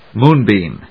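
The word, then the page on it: moon-beam: móon・bèam; ˈmuˌnbim (en-us); ˈmu:ˌnbi:m (uk)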